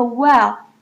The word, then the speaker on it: while: 那第二个呢是关于 while 的用法，平常我们单念 while 的时候呢，我们的嘴巴张得非常的开， while 就很像 while，那可是在念比较快的句子的时候， while 可以听到它会变得很像 well， good 的副词 well 的发音。所以呢在这边我们可以看到 of the world's energy, the US consumes about 25%, while Google alone accounts for roughly 0.013%。有没有看到我的那个 while 是念成 well 的音， well 很快的时候就会变成 well 的音，这边要注意。